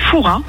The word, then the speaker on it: Fouras: Eh bien écoutez, on dit Foura. Ne prononce pas le S.